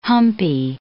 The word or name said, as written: humpy